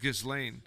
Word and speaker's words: Ghislaine: It's pronounced Ghislaine.